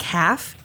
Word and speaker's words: Half: It's spelled like half, H-A-L-F. – Oh. Okay. – But it's pronounced Half. It's a German name.